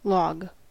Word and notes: log: /ˈlɑɡ/

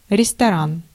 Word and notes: restaurant: Belgique (Bruxelles); [ʀɛs.to.ʁɑ̃]

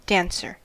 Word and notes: Tänzer: /ˈtɛn.tsɐ/

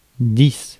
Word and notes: ten: /ˈtɛn/; [ˈtɛn]; [tʰɛn]

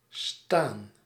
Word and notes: indiquer: [ɛ̃.di.ke]; France

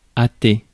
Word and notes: hasten: /ˈheɪs.ən/

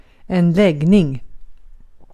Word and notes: läggning: [²lˈɛɡːniŋ]

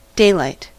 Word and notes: daylight: /ˈdeɪlaɪt/

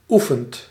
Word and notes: oefent: /ˈu.fənt/

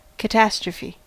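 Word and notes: катастрофа: IPA: /kətɐˈstrofə/; Russian

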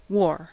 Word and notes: wore: /woɹ/; /wɔɹ/; /wɔː/

